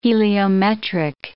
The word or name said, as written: heliometric